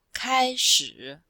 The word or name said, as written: kai1shi3